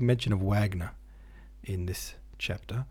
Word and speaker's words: Wagner: Wagner, pronounced Wagner.